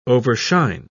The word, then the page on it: overshine: British English